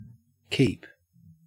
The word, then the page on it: keep: Australian English